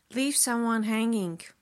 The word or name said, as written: leave someone hanging